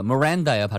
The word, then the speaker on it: Miranda: 네, 예전에 미란다라고 하는 거 Miranda야 발음이요. 미란다라는 사람이 체포됐는데 자기 권리를 듣지 못해서 he stated something against his situation.